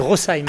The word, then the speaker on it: Rosheim: Alors on va dire un truc pour tous les Français qui nous écoutent, le SH dans les noms de villages alsaciens se prononce « se » et non pas « che ». Donc c'est Rosheim, hein, le très beau village de Rosheim.